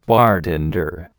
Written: bartender

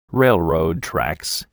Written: railroad tracks

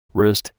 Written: wrist

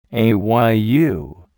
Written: ayu

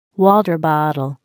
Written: water bottle

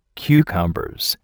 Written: Cucumbers

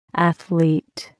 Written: athlete

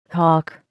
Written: hawk

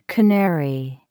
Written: canary

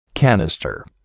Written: canister

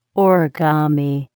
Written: origami

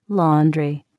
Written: laundry

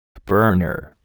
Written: burner

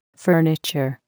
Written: furniture

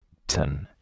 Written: Ten

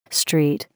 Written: street

